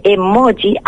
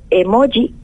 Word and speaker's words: emoji: porque la J castellana me cuesta muchísimo, como yo creo que a la mayoría de los canarios, y decir también, yo diría emoji, entonces suena un poco raro, Maru, así me van a permitir que lo diga como emoji, y ya decía, escribirla en cursiva si la pronunciamos así.